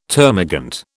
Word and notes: termagant: British English

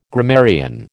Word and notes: grammarian: American English

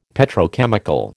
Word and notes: Petrochemical: American English

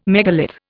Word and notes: megalith: Indian

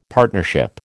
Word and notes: partnership: American English

